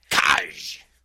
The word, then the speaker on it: Kaaj: I really like this one too, and I love that they're building up this Kaj character, K-A-A-J. How do you pronounce that? – Kaj.